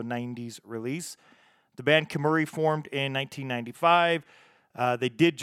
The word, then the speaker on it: Kemuri: Let's keep those positive tracks, those positive ska vibes rolling with the next band out of Japan, Kemuri, K-E-M-U-R-I. K-E-M-U-R-I. Okay, I did say that right.